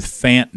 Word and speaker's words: Fant: And a pronunciation question, Noah Fant, is it Fant or Font? I can't get a definitive on that. – I've given up on it, and I have just I've gone with Fant now.